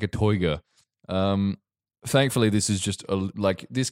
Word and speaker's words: Toyger: Sort of pronounce it like you were a villain in Austin Powers. – Please, have demonstrate. – Toyger.